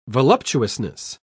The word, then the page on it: voluptuousness: British English